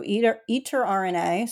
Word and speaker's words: Eterna: It's spelled E-T-E-R-N-A, so Eater RNA. So thinking like RNA, which is something you might have learned in biology or genetics class.